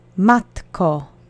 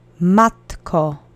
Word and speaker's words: Matko: The accent is on the first syllable. Repeat after me. Matko. Matko. Matko.